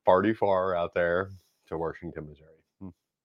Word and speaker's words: Washington: It's actually, we don't merge syllables together. We put an R in, so it's Washington.